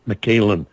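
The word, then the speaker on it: Mikalyn: And for those of you who aren't familiar with her name, it's M-I-K-A-L-Y-N. And every time I write it down, I try to move the Y out of place just because of the way her name is pronounced. But it's M-I-K-A-L-Y-N, McKaylin.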